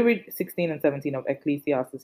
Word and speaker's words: Ecclesiastes: King Solomon, the wise King Solomon, even in Ecclesiastes, oh, that word, I'm surprised, I'm glad I pronounced it properly. Ecclesiastes chapter 8, verses 16 to 17.